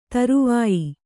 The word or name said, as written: ಧ್ರೌವ್ಯ